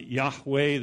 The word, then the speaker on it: Yahweh: We pronounce it Yahweh. The New King James translates it as Jehovah, but it's the name that was revealed to Moses clear back in Exodus chapter 3.